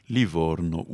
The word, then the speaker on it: Livorno: aujourd'hui, nous vient de Livorno. Est-ce que c'est comme ça qu'on prononce ? – Livorno ou voilà. Livourne.